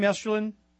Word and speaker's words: Estulin: Daniel, is that how I say your last name, Estulin? – Yeah, you said it perfectly well.